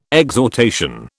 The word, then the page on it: exhortation: British English